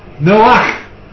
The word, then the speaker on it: Noah: We call him Noah, don't we? But in the Hebrew, it's pronounced Noach. Noach. Noach and Noah are not the same.